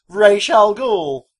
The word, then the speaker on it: Ra's al Ghul: Ra's al Ghul. – The big bad guy is Ra's al Ghul. – Ra's al Ghul. – Pronounced Ra's al Ghul. Correctly. Excelente.